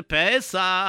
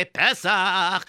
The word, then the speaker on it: Pesach: Because we're getting closer to Pesach, as some people say Pesach, which is the correct pronunciation, but everybody got so used to the Yiddish way of saying Pesach or Pesach or Pesach.